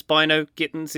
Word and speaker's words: Bynoe-Gittens: pronounced the goal scorer, that second goal goal scorer for Dortmund, incorrectly. It's England's Bino Gittens is how we say it.